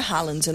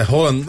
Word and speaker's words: Haaland: Corrigiu, ele falou que não é Haaland, né? Que pronuncia. – É Haaland. É meio Haaland.